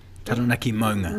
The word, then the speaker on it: Taranaki Maunga: And also we have the Taranaki Munga. – Taranaki Maunga. – Mona.